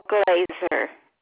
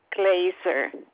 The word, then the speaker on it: Glaser: Glaser. – Could you spell that last name, ma'am? – G as in George, Laser, Glaser.